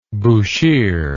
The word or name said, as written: bushire